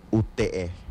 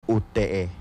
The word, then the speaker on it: ute: Y bueno, en árboles, en lengua yocotán se pronuncia UTE. Vamos a repetirlo, UTE.